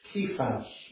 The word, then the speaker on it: Kephas: I've been pronouncing it a certain way for many years, but looking today, it's to be pronounced Kephas, more from the original.